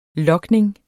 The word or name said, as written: logning